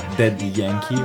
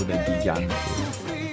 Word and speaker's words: Daddy Yankee: Sì, invece con me torniamo in Sud America, sempre in Porto Rico, perché il cantante che vi propongo è abbastanza famoso, è Daddy Yankee o Daddy Yankee, che dir si voglia, dipende dalla pronuncia, se spagnola o inglese, e la canzone è La Despedida del 2010.